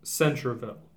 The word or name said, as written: Centreville